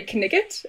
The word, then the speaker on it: knight: But I remember in this particular recording, instead of knight, they would say kniggit. It was just great. Yeah. They would all of the like Monty Python. – Yeah, exactly. All of the pronunciation would follow the spelling.